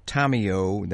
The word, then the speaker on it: Tomeo: It's Teresa Tomeo. That's spelled T-O-M-E-O.